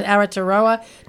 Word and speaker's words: Aotearoa: Yeah, this festival is actually particularly interesting because it features the best Australian, as it's pardon my Maori pronunciation, Aotearoa, New Zealand and Pacifica women's and gender diverse stories on screen. Is that right? – That's correct. Yeah,